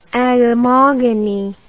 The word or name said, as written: agamogony